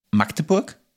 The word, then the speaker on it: Magdeburg: Meine beiden Zusatznotizen, die ich hier habe, ist Schulen und die Universität in, und jetzt Achtung, Magdeburg. Das Wort Magdeburg bitte richtig aussprechen, das heißt nicht Magdeburg. Scheinbar muss man, also ich vermute, dass es A sehr kurz ist.